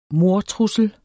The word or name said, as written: mordtrussel